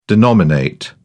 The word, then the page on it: denominate: British English